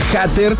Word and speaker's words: hater: El término hater, o bueno, se escribe H-A-T-E-R. Hater se pronuncia hater.